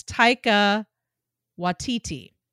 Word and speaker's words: Taika Waititi: I think it's Taika Waititi, right? That's maybe how you say it. Taika. – Yeah. – Yes. Taika Waititi.